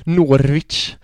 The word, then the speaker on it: Norwich: Eh, Arsenal 1, Norwich... Åh oh, för fan, hörde du mitt svenska uttal eller? Ja. Norwich. No Norwich.